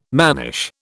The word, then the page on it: mannish: British English